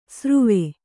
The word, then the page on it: ಸಾರುವೆ: sāruve